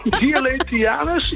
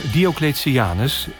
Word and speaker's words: Diocletianus: Het paleis van Cletianus. Het paleis van Nou, nog een keer. Diocletianus in Split, Kroatië.